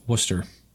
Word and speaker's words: Worcester: That town is pronounced Worcester. – Oh, so it's not like the sauce.